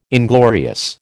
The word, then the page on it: inglorious: American English